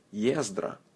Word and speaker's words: Ездра: Ездра.